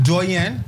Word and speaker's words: doyen: How you say it again? – Doyen. D-O-Y-E-N.